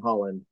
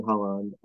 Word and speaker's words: Haaland: So Erling Haaland, we have to just mention this. Erling Haaland, or Haaland, I think is how it's pronounced, because he's in Norwegian.